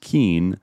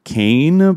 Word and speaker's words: Kean: I am a stickler for it, and I guess we showed our primitive Irish asses by assuming that her name Kean was pronounced Keen, but apparently not. According to Ezra Klein, who probably went to the right prep schools and learned how to do this, kept pronouncing it on the most recent podcast as like Kane, but with like a little bit of an inflection of like Kane.